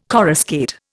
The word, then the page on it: coruscate: Indian